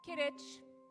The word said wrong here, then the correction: Quidditch: Quidditch. Quidditch. – Sorry, I said it wrong.